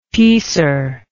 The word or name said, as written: piecer